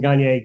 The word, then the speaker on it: Gagne: French pronunciation is Gagne. Gagne is how everybody pronounces it.